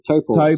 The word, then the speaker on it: Taupo: Taupo. – So Taupo is going to get that one. – As in, your little toe doesn't have any money. Taupo.